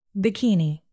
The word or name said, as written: bikini